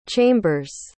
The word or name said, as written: chambers